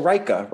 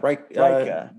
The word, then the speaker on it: Ryca: It's Ryka, R-Y-C-A maybe. They make the R-Y-C-A. Oh, Ryka. – Ryka.